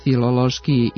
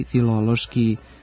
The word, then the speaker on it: filološki: Ako medicinski, filološki i filozofski izgovarate na ovaj način, sa akcentom na prvom slogu, razgnevit ćete pre svega naše normativiste, budući da standardno jezička norma ovdje prihvata samo kratko uzlazni akcenat na drugom slogu. Dakle, medicinski, filološki, filozofski, biološki, pedagoški.